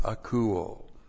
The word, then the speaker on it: akouo: And then we have the verb akouo. This is a great word. A-K-O-U, long O.